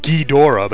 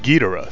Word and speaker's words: Ghidorah: Not Ghidorah, but Ghidorah. So, it's an emphasis issue. Where do you put the emphasis? On which syllable, so to speak?